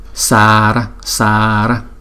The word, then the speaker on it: Sara: Và tiếng Phạn của câu chú này là Sara Sara. Chúng ta phát âm là sờ, sờ như màu xanh đó, Sara Sara.